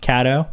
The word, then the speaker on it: Caddo: Day six starts in the swampy bayou country on the beautiful Caddo Lake. Am I saying that right? Is it pronounced Caddo? – You are.